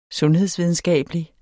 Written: sundhedsvidenskabelig

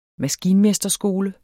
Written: MASKINMESTERSKOLEN

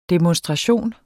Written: demonstration

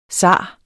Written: zar

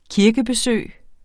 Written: kirkebesøg

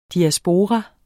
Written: diaspora

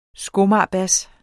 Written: skomagerbas

